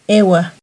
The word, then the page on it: ehuah: Náhuatl